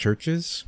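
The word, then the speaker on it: Chvrches: So before that, I wanted to say I don't remember when that came out, but I think it's pronounced Churches, but it uses a V instead of a U.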